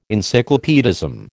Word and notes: encyclopaedism: American English

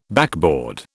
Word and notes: backboard: British English

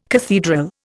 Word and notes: cathedral: Indian

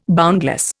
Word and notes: boundless: Indian

